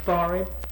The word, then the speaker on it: forehead: The parts of the body you call this part of the forehead. And by the way, that has to be the pronunciation, otherwise it wouldn't rhyme. In the poem, there was a little girl and she had a little curl right in the middle of her forehead.